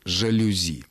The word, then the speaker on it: жалюзи: Галина, правильным остается то, что вы читаете в орфографическом словаре. Тефтели, гренки и жалюзи.